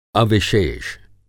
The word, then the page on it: अविशेष: Hindi